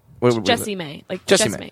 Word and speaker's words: Jessimae: Jessimae.